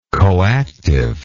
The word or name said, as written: coactive